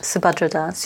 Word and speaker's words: Subhadra Das: So you also quote, again, I hope I pronounce this right, Subhadra Das? – Subhadra Das.